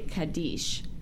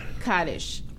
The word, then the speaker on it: Kaddish: Well, it's okay, it depends on Ashkenazic Hebrew or Sephardic Hebrew, how it's pronounced. – Okay. – An Israeli friend of mine thought I was mispronouncing it because they would say Kaddish. But the Eastern European call it Kaddish.